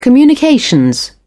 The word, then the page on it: communications: American English